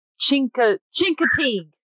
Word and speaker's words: Chincoteague: Chesapeake or Chesapeake ponies or whatever. Those are... That's not how you say that. You idiot. Hold on a second. Chincoteague.